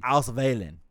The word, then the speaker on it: Auswählen: It's German, so the W's are pronounced like V's. – Okay, so like Volkswagen is like Volkswagen. – Yes. Like so Volkswagen. – Auswählen.